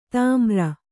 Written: ಧೂಮ್ರ

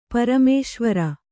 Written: ಪರಮೇಶ್ವರ

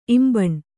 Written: ಇಂಬನಿ

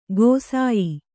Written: ಗೋಸಾಯಿ

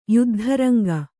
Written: ಯುದ್ಧರಂಗ

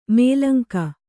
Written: ಮೂಲಂಗಿ

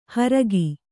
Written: ಹೋರ್ಕೆ

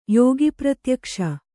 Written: ಯೋಗಿಪ್ರತ್ಯಕ್ಷ

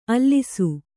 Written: ಅಲಸು

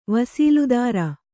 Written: ವಸೀಲುದಾರ

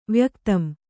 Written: ವ್ಯಕ್ತಂ

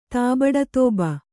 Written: ತಾಬಡತೋಬ

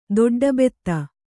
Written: ದೊಡ್ಡಬೆತ್ತ

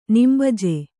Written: ನಿಂಬಜೆ

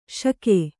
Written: ಷಕ್ಕು

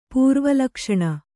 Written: ಪೂರ್ವಲಕ್ಷಣ

